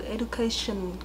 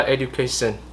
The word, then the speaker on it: education: Với cả cái từ education, cái shin của bạn ấy, bạn ấy phát âm chưa đủ nặng. – Ừ, bạn ấy nói là education.